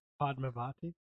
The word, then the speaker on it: Padmavati: Padmavati. – Padmavati.